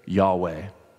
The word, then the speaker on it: YHWH: The word Lord, when spelled with capital letters, stands for the divine name YHWH. We say Yahweh, which is here connected with the verb Hayah, to be, in verse 14.